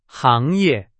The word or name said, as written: 行业